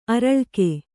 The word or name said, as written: ಆರಾಲಿಕ